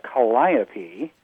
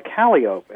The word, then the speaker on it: calliope: And someone down there was calling what I would call a calliope. They would pronounce it a calliope. And I said, ooh, you're wrong. They said, no, it's a calliope when it's on land, and it's a calliope if it's on a steamship, a riverboat.